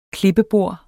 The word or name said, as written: klippebord